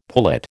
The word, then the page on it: pullet: American English